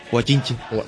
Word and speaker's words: guachinche: Además, ¿puedes decir guachinche? – Guachinche. – Claro, es que pronuncié así un poco. Claro, nosotros decimos guachinche con toda la letra y vosotros pronunciáis la che de una manera diferente, ¿no?